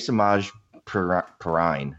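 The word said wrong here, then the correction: Samaje Perine: who I never pronounce his name correct, or the same two times in a row. – Yeah. Isn't it Samaje Perrine? – Yeah, say he runs away with that running back one role in Washington this year, and it actually is good.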